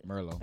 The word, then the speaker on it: Merlot: Merlot. – Thank you for that. – Merlot. The tea is silent.